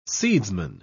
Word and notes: seedsman: British English

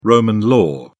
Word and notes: roman law: British English

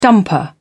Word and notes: dumper: British English

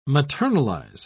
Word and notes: maternalize: British English

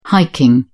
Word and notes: hiking: British English

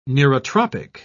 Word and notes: neurotropic: British English

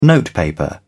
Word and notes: notepaper: British English

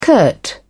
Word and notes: curt: British English

